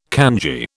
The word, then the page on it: kanji: British English